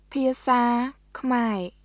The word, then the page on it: ភាសាខ្មែរ: ピアサー・クマエ; pʰiˑəsaː kʰmae